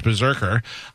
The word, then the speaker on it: BRZRKR: It's spelled B-R-Z-R-K-R, but it's pronounced Berserker.